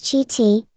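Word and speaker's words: Chiti: Harry Dominic Cheaty Jr., pronounced Cheaty, November 16, 1932, January 31, 2002, was an American catcher in Major League Baseball.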